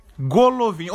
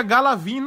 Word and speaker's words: Golovin: Golovin. Ou eu que tô viajando e tipo na Rússia o Go pronuncia H. Porque todo mundo pronuncia Galavin, não é Galavin, pô.